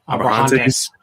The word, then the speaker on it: Abrahantes: Yeah, I thought that match was a little bit worse, especially like as much as I like Alex Abrahante. How do you say his name? – Abrahantes. – Yeah, Alex Abrahantes.